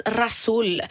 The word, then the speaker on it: ghassoul: Det är alltså Gasol, stavas det. G-H-A-S-S-O-U-L lera från Zagora. Fast det uttalas Rasol.